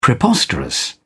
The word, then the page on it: preposterous: British English